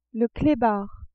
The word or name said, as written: clebard